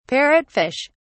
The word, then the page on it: parrotfish: British English